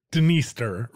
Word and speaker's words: Dniester: You might want to pronounce this the Dniester River, but the D is silent.